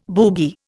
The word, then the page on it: boogie: Indian